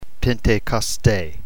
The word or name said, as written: pentekoste